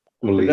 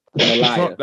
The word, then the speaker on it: Malia: Malia. – That's how you say her name? Oh, Malia. – Malia. What you call her, Melania? – Malia. – That's the Trump lady. – Not Melania, Malia.